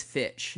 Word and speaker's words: Fitch: Abercrombie and Finch. Like, I like girls who wear. – I say Finch as well. It is Fitch.